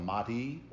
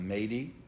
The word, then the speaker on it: Mahdi: He is known as the Mahdi or the Mahdi. People pronounce it both ways.